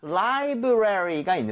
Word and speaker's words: library: 자, 라이브러리가 있는데 얘는 사실 러리로 하는 사람도 있고요. 발음을 레리로 하는 사람들도 있는데요. 저는 개인적으로는 좀 레리에 가깝게 하는 편이기는 해요.